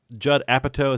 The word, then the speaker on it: Judd Apatow: Number one is, who is is it Judd Apatow? Is that how you pronounce his name? – Yeah.